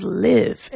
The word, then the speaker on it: Live: Also, a little tidbit because I know way too much music trivia from that time period, but the guitarist said that it is Live, and everyone has been mispronouncing the band's name forever.